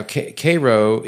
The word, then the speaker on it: Cairo: Then, after further confusion, he was eventually transferred back to command of southeast Missouri, at which point he moved his headquarters to Cairo, Illinois. Now, Cairo is pronounced like the syrup, but it's spelled like the city in Egypt.